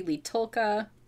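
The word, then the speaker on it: Li Tolqa: And it's in a fictional country, Leetulka. I have to be careful with the pronunciation because I know someone named Tolka. – Oh, okay. – So, but there's an L in there for this, Leetulka.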